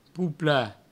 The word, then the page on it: poplar: poupla; provençal